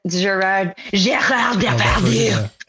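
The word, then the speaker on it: Gérard Depardieu: I love the Gerard Depardieu version. Sorry, I had to say his name. – That's the only way you can say it.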